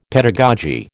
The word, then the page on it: pedagogy: American English